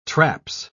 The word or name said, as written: traps